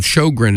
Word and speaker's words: Sjowgren: Meantime, though, we're going to change gears a little bit with a latest single from a band called Shogren, and it's spelled S-J-O-W-G-R-E-N.